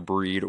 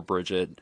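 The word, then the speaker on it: Brigid: Her name can be pronounced as either Breed or Bridget. It just depends on which reading or which tradition you follow.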